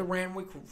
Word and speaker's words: Randwick: And you're the kind of person who would go to the Randwick, yeah, that's how you say it.